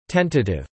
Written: Tentative

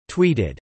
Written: tweeted

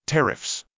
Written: tariffs

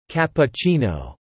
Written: cappuccino

